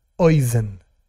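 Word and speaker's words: Oizen (אוזן): La palabra oído, en hebreo se escribe Aleph, Vav, Zain, Nun, Oizen. Oizen, no importa ahora que los detalles, ¿no? Pero oizen, así se dice en hebreo oído.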